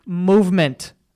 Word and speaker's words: MVMT: MVMT watches, pronounced movement.